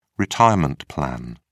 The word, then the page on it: retirement plan: British English